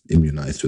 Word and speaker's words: immunized: Also eine Formulierung zu wählen, äh, I am immunized, immunized, immunized wird es ausgesprochen, also immunisiert auf Deutsch, ähm, auf die Frage, ob man denn geimpft sei.